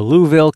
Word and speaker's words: Louisville: Just had a fantastic week here in the Equip Expo in Louisville, Kentucky, or Louisville, Kentucky. I think it's properly pronounced.